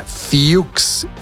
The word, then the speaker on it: Fuchs: Dana Fuchs. Dana Fuchs. Así se pronuncia, pero se escribe F-U-C-H, que podría pronunciarse Fox. Entonces, bueno, hay confusiones por todos lados, pero bueno, la pronunciación correcta es Dana Fuchs.